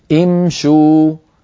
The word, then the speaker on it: امشوا: إذن امشي، فلما امشي نزيد لها واو الجماعة، لا يتأتى أن نقول امشوا امشوا، بل نضم الشين، لسنا نحن بل العرب كانوا هكذا يفعلون، يضمون الشين من أجل مجانسة واو الجماعة، وهذا أسهل عليهم في النطق، فيقولون امشوا امشوا، فتبقى همزة الوصل مكسورة، الشين مضمومة من أجل مجانسة واو الجماعة، وهمزة الوصل في الأول مكسورة.